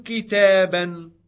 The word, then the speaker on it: كتابا: For example, كم كتابا. – How many books. – كم سيارة. – How many cars. Note that a noun with tanween in the accusative case takes an aleph, which is not pronounced.